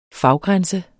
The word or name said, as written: faggrænse